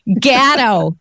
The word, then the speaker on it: Gatto: We feel so close to him because he pronounces his name like a true Rochesterian would. Gatto.